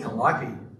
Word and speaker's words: Calliope: I think I used to say Calliope, and they're like, Calliope.